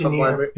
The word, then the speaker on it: Dimir: We came into the library of Dimir. I think it's Dimir. – Very Dimir. – That's how we're pronouncing it.